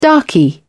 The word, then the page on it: darkie: British English